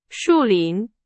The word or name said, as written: 树林